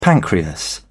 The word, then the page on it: pancreas: British English